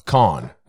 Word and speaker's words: Kohn: K-O-H-N. – K-O-H-N? – Yeah. – Go coon. Calm. – Is it coon? – Calm. Calm. – There's no M. – I'm not saying N. Calm. – Oh, con. That's what.